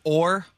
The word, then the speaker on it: Oher: In fact, we forgot about Michael Oher. Is that how you say his name? – Or? – I like Oher. I'm just saying. – Okay. You asked me how to say it, and I told you.